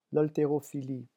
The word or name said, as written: l'halterophilie